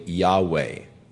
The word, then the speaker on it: Yahweh: It is the YHWH tetragrammaton is unpronounceable, but according to most scholars, the pronunciation is probably Yahweh.